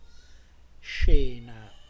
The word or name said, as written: scena